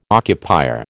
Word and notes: occupier: American English

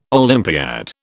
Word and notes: Olympiad: British English

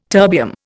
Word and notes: terbium: Indian

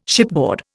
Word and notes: shipboard: Indian

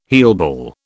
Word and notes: heelball: British English

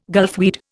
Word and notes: gulfweed: Indian

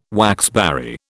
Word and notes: waxberry: British English

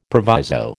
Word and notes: proviso: American English

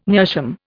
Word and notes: meerschaum: Indian

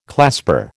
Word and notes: clasper: American English

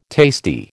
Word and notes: tasty: American English

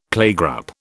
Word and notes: playgroup: British English

